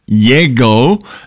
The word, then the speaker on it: Llego: the track is called Yego, and it's spelled LLEGO.